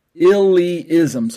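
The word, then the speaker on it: illeism: I looked up the word, and I ended up pronouncing it as illism. But it's actually illism. Illyism. So I-L-L is ill, and then the E gets pronounced E, and then the second I, I. Illyism.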